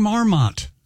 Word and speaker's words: marmot: And by the way, coming up next marmot? – Week. – Stop saying marmot. It's marmot. – It's spelled with an O. – I know, but it is marmot, usually pronounced more casually.